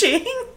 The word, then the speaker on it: Sean: Or, like, he sees Sean, like, write his name, and he's just like, I thought your name was Shane. And Sean's like, yeah, it's just pronounced Shane. – Oh, spelled Sean.